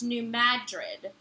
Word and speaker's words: New Madrid: Bonnie know that the New Madrid fault is pronounced New Madrid. Emphasis on mad, not drid, like in the city in Spain.